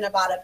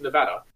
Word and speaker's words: Nevada: Okay, first of all, it's pronounced Nevada, and I'm from Las Vegas.